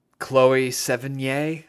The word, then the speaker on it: Chloe Sevigny: Chloe Sevigny.